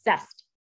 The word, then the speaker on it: CEST: She is the co-founder of Story Emotional Social Therapy called CEST, kind of pronounced like test, which is an interdisciplinary therapy model bridging the speech language pathology and mental health fields.